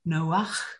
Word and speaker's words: Noach: It was at that point something stirred in the back of my mind as I remembered that Noah is not the Hebrew pronunciation of his name. If you look up Noah's name in the Hebrew, you will find that it is actually meant to be pronounced Noach with a chet at the end.